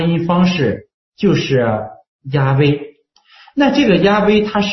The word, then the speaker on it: 亚威: 这是今天被普遍接受的公认为是正确的发音方式是亚威。